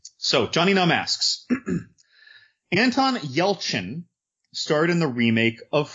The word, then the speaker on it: Yelchin: Is that how you say his name? – Yelchin.